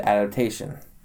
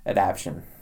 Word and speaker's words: adaptation: You said adaptation. – Yes. – That's because that's how it's pronounced, right? – Right. – I've heard too many people that pronounce it adaption.